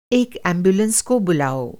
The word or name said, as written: एम्बुलेंस